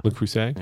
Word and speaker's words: Le Creuset: Le Creuset.